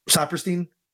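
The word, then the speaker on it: Sapirstein: Pronounce it? Yeah. Saperstein.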